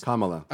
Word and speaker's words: Kamala: Kamala. – Kamala. What am I saying? It's like Kama. – Kamala. You just put the emphasis on the second syllable, and it's on the first.